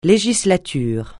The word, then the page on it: législature: francais